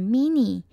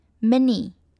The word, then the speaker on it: mini: 再来是 mini, mini 的 I 其实是短短的音，很多人会习惯念成 mini, 但是其实我们要念的短一点 mini, mini.